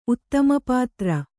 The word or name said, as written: ಉತ್ತಮಪಾತ್ರ